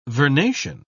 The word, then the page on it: vernation: British English